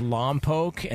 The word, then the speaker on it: Lompoc: And by the way, Lee, it's Lompoc, and the only reason I know that's the correct pronunciation is because they beat us 2 years in a row in the playoffs my high school football career.